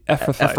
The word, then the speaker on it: Ephrathites: Uh, the the the Ephrathites.